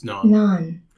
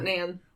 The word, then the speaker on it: naan: Okay, so here's the pronunciation of naan. – It's naan. – Naan. – Naan. – Yeah, not nan.